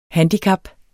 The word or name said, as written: handicap